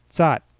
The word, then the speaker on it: tsat: For example, let's say I have a word tsat. In Na'vi, this would be pronounced tsat. Now you'll notice you don't hear that extra t at the end, but you do still hear the t.